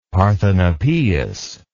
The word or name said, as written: Parthenopaeus